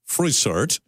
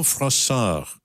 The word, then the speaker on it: Froissart: You can think of this as a work titled Froissart, as in John Froissart, or as in the Belgian-French pronunciation, Jean Froissart.